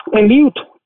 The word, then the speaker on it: Eliut: Eliut. – E-L-I-U-T.